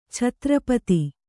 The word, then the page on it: ಛತ್ರಪತಿ: chatra pati